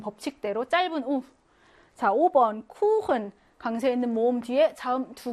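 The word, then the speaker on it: Kuchen: 예외여서 원래는 법칙으로는 짧아야 하지만 쿠흔 하고 길게 발음한다.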